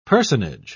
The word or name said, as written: personage